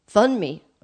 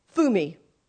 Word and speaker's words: Funmi: But her name is spelled Funmi. So if you were just an American looking at her name, you'd think it's Funmi, okay? Funmi, F-U-N, right? But the N is... That's getting a little detailed for your information. I'm sorry. But so anyway, you pronounce her name Fumi, Dr. Fumi.